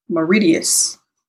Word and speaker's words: Mauritius: It's funny because I used to always say this wrong. It's Mauritius. – Hmm. – Yeah, okay. I just always say Mauritius.